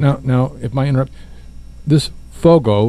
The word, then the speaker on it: Foggo: So this Dusty, is it Fogo? – Fogo. F-O-G-G-O. – Okay.